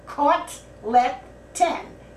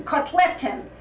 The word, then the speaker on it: kotleten: Chaglecken? – No, no. Just the way I pronounced it. Kotleten. K-O-T, kot, L-E-T, let, A-N. Kotleten, The Yiddish word for Bubby's hamburgers. Kotleten. Right. You finally did it. Right. Do it again. Say kotleten. – Kotleten.